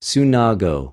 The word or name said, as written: συναγω